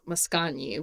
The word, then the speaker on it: Mascagni: Cavalleria Rusticana, intermezzo by Mascagni, which is not a piece that I had listened to myself, but apparently my dad really loved this song.